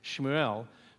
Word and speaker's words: Shmuel: So that's how they would have pronounced Samuel, Shmuel.